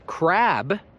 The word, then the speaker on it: crab: Na przykład mamy słowo crab, crab, crab i słowo crap. Crap to jest tak jak coś jak gówno. To chyba jest duża różnica między crab i crap. Słyszysz, jeden jest p, jeden jest b.